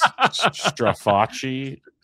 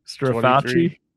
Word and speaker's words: Straface: It rhymes with Versace in case it comes up. Oh, Straface. So it's Strafacci. – Strafacci.